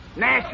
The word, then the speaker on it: gnash: Nash is spelled G-N-A-S-H, and the E is silent preceding the N.